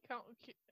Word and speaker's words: Couney: Dr. Martin Corney, and I could be saying this wrong, C-O-U-N-E-Y, Coney? County? – County? Yep, that's, yep.